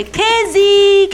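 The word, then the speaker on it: kesi (茄子): Lo sapevi che quando scattano una foto i cinesi spesso dicono la parola melanzane invece di dire formaggio, perché la pronuncia di questa parola è kesi. Kesi! Kesi, kesi, kesi!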